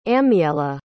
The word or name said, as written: amiela